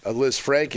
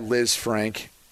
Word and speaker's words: Lisfranc: And in doing so, you end up damaging that Liz Frank ligament. Some people pronounce it Liz Frank, by the way.